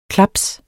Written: klaps

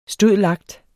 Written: stud.lact.

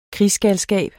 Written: krigsgalskab

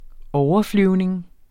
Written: overflyvning

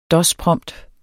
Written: dosprompt

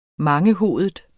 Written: mangehovedet

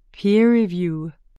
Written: peerreview

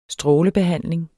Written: strålebehandling

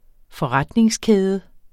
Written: forretningskæde

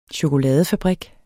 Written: chokoladefabrik

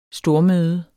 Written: stormøde

